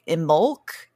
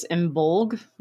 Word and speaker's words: Imbolc: You'll also hear very different pronunciations of I only know Imbolc, but there's like Imbolc and yeah. What's the other one? – So there's Imbolc and then there's Imbolg, like with the kind of G.